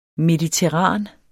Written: mediterran

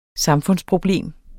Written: samfundsproblem

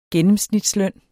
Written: gennemsnitsløn

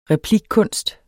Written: replikkunst

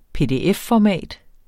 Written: PDF-format

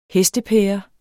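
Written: HESTEPÆRE

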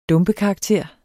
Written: dumpekarakter

